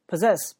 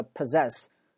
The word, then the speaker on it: possess: Possess 表示拥有的意思，它是一个动词，它读的发音你要注意，叫 possess。很多学生读成 possess，它不是在，它不是啊、你在哪儿啊？那个在，它不是在，它是 z possess，z possess。